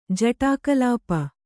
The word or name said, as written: ಜಟಾಕಲಾಪ